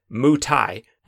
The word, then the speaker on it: Muay Thai: And if that's the case, you're going to want to join me tomorrow as we discuss what I am assured is pronounced Muay Thai on MDC.